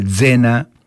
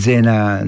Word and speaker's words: Zena: Yo alguna cosa entiendo, por supuesto, vuelvo a repetir, algún oído tengo, pero la verdad que Zena, asociada con Génova, no es Zena, no se pronuncia tanto la Zeta, son más suaves, Zeta.